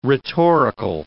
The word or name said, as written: rhetorical